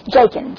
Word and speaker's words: Jakin: One of them was named Jokin. I never can say that right. Jakin, J-A-K-I-N.